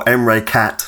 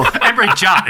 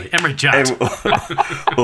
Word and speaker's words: Emre Can: I would either go for Emre Cat or... – Emre Jot, it's pronounced, actually. Emre Jot.